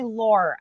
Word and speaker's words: Lore: I always tell people, just call me Lore, L-O-R, because it's spelt like Lorelei, and it's Lorelei.